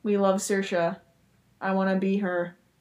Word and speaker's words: Saoirse: Yeah, Saoirse.